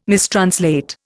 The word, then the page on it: mistranslate: Indian